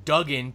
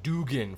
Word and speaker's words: Duggan: And thank you for actually using the word Duggan. People have fought me forever on the pronunciation of Duggan. Some people have said Dugan for the longest time.